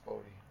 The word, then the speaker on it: Bode: It's spelled Bode. – Yeah, but it's Bode. – There's no accent aigu.